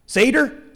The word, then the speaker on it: Seder: It would come to include a special meal, S-E-D-E-R, the Seder or the Seder meal. I'm a Hoosier. I don't know how I'm supposed to pronounce that up here. Seder? Cedar? Oh, Seder. Okay, we'll go with that.